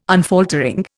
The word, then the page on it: unfaltering: Indian